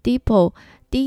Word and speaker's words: Depot: The Home Depot 这家店的店名发音一开始让我觉得有点困惑，因为它最后一个单字 Depot，D E P O T，它的 T 是不发音的。